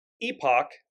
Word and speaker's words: epoch: And then the last thing they said was an awakening, in quotes, an awakening of epic and epoch, that's how it's pronounced in British English, epoch proportions.